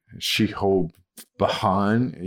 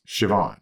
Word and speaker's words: Siobhan: And even some Irish names, like if you see Shehobahan, you know, it's Siobhan, right? – Right. – It's not Shehobahan. – Right. – Like it's not pronounced the way it's spelled.